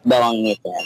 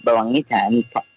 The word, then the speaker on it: Balangitan: Tell the people who Ace Balangitan is, and hopefully I'm pronouncing that properly. – It's Balangitan. – Balangitan. – So, I'm Ace Balangitan.